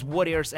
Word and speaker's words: Worriers: Uh, it is Worriers, and it's pronounced not the people who fight, it's people who worry. So it's Worriers spelled with O.